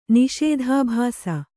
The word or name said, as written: ನಿಷೇಧಾಭಾಸ